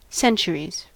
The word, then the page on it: centuries: American English